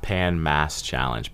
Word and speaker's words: Pan Mass Challenge: The Pan Mass Challenge, PMC, Pan Mass Challenge.